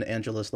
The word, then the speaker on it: Angelus: Mine is I'm Christian like the religion, Angelus like Los Angeles, and yet everyone still gets my name wrong, which is very bizarre.